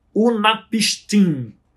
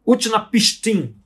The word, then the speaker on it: Utnapishtim: Num ponto da história, aparece um tal de Unapistim. Utnapishtim.